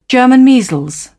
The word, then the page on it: german measles: British English